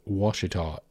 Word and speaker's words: Ouachita: It is not phonetic. It is an indigenous word, and it is spelled O-U-A-C-H-I-T-A. Any of you guys watching on YouTube, just watch me close my eyes and spell it out in my head. I didn't put it right in front of me. O-U-A-C-H-I-T-A. It's spelled kind of like Ouachita. Yeah, and it actually probably was sort of pronounced that way originally.